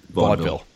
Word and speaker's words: vaudeville: Everybody on Periscope is saying it's two syllables. Vaudeville.